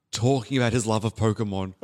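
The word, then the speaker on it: Pokémon: Yeah, because we say Pokemon, like Pokemon in Western culture, but it's Pokemon.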